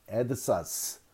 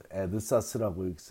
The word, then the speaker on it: AADSAS: 에드사스 AADSAS 에드사스라고 읽으시면 됩니다. 발음하시면 됩니다.